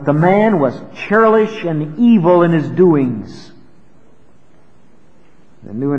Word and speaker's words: evil: Evil would be the way it would be pronounced. In English letters, it would be spelled like evil, E-V-I-L.